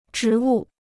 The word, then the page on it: 植物: zhí wù